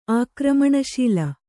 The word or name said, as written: ಆಕ್ರಮಣಶೀಲೆ